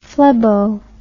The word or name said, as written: phlebo